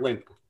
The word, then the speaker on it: Lindt: I don't think it's Lindt, like pocket Lindt. – It's like Lindt. – Yeah, it's like Lindt. – Like Pam? – But you say it fast. – Pam? Like Step Brothers, Pam? – Lindt. – Yeah. – Yeah, like that's how you're supposed to say it. – Wait, yeah. – Say it. – It's Lindt. A silent T.